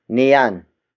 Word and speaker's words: niyan: The next pronoun is niyan. Niyan. Niyan. Niyan is spelled as N-I-Y-A-N.